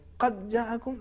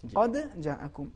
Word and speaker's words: قد جاءکم: ادغام نہیں کرنا چاہیے قد جاءکم صحیح نہیں ہے قد جاءکم تو صحیح تلفظ اس طریقے سے ہے یا ایھا الناس قد جاءکم دال کو جیم میں ادغام نہ کریں متصل نہ کریں اس کو الگ تلفظ کریں